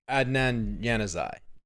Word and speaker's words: Adnan Januzaj: I think it's Andon Januzaj. – Adnan Januzaj. – Okay, that's actually pretty good for me.